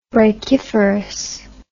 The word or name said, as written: brachiferous